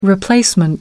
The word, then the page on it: replacement: American English